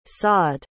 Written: sawed

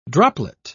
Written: droplet

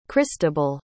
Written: christable

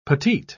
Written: petite

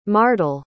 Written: mardel

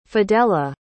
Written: fidella

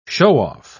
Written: show-off

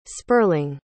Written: sperling

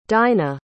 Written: dinah

